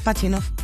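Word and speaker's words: Pajitnov: Os presento a Alexei Pajinov seguramente lo estoy pronunciando mal. Si hay algún user ruso, yo lo siento. Yo conozco, es Pachinov. – Pachinov, ¿verdad? Pues ahí está. Eh, ahí está Pachinov.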